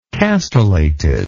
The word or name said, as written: castellation